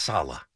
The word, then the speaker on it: Saleh: Can we get his name right? It's Saleh. I mean, let's at least get the guy's name right.